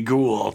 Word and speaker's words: ghoul: And he now is like, how do you say ghoul? And like, it's like cool with a G, ghoul.